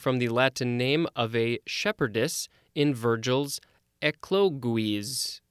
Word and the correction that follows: Eclogues: Oh, I'm sure I said that wrong. Eclogues. Next, we have the word amass, A-M-A-S-S.